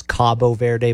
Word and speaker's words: Cabo Verde: You know, but the thing is it Cape Verde or Cape Verde? – The right pronunciation is Cabo Verde, but they need to change there you go.